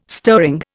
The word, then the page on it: stirring: Indian